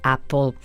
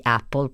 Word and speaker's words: Apple: Apple, perché spesso in Italia si pronuncia Apple, ma gli americani invece la dicono proprio con la A, ve lo assicuro.